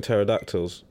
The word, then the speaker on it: pterodactyl: What if it's a silent P? Like, for example, if the story is involving pterodactyls...